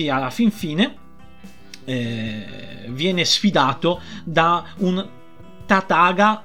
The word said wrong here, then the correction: Tathagata: no scusate, da un Buddha che si chiama Tathagata. Ho detto bene, sì, Tathagata.